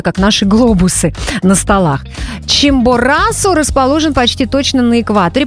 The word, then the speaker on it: Чимборасо: И мы отправимся туда, к одной из самых удивительных гор на нашей планете, вулкану Чимборасо, точнее Чимборасо, но на самом деле мне выдал ударение и то, возможно, это специфика местного языка. Поэтому будем говорить Чимборасо, как говорят иностранцы.